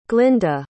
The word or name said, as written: glynda